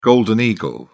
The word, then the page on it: golden eagle: British English